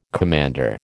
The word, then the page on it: commander: American English